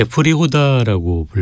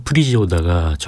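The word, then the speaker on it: Příhoda: 한테 프리호다라고 불렸는데요. 프리지호다가 정확한 발음이고요.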